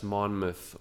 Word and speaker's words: Monmouth: People in the Midwest don't know how to pronounce the names, and I found out it's Monmouth, Oregon.